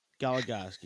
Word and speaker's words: Goligoski: Goligoski, yep, that's it.